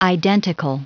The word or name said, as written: identical